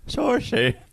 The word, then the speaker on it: Saoirse: and the robot told us it was pronounced Saoirse Ronan.